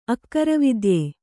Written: ಅಕ್ಕರವಿದ್ಯೆ

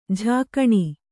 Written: ಜಕಣಿ

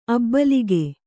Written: ಅಭಿಲೇಖ

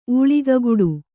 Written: ಊಳಿಗಗುಡು

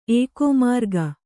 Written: ಏಕೋಮಾರ್ಗ

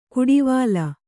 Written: ಕುಡಿವಾಲ

